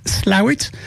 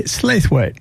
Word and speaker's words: Slaithwaite: Well, it's pronounced Slawit, but if I spelt it out for you, S-L-A-I-T-H-W-A-I-T-E, you would say Slathwaite. – Slathwaite. – But the local pronunciation is Slawit.